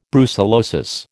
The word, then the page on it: brucellosis: American English